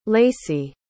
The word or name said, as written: lacie